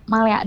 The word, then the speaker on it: Malaya: Wah luar biasa, kakak bener nih dari ownernya Malaya ya, betul dibacanya Malaya bukan? – Iya betul, Malaya dibacanya. – Malaya, apa bener ya Malaya ya? – Ya betul, Malaya Bakehouse, Kak.